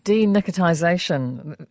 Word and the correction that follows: Denicotinisation: am I saying that right? Denicotisation? – Denicotinisation. – Denicotinisation, yeah. Okay.